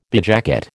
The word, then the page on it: bedjacket: American English